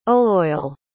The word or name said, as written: oleoyl